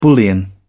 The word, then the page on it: bullion: British English